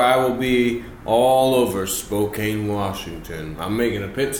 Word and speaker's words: Spokane: I say Spokane. – Yeah. – Spokane, Washington. – Mm-hmm. Spokane like a true American.